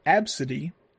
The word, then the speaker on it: Abcde: whose name is pronounced Absidy, and is spelled A-B-C-D-E.